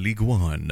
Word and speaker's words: Ligue 1: The Serie A, Bundesliga, and Ligue 1. – Wow, you pronounced it correctly for the first time? – Yeah, I know.